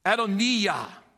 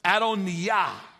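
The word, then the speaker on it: Adonijah: Adoniah is the way you pronounce it. Adoniah.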